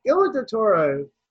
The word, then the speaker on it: Guillermo del Toro: It's Guillermo del Toro.